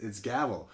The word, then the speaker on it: Gävle: It's Gävle.